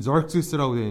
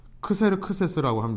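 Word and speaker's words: Xerxes: 그랬던 바사의 이제 아하수에로라고 하는 왕 혹은 영어로 보면 절크시스라고 되어 있는데 크세르 크세스라고 합니다. 이 두 가지 이름으로 불려요. 아하수에로는 이제 히브리식 발음이고요. 크세르 크세스 이 절시스라고 하는 말은 아람 말 발음으로 되는 겁니다.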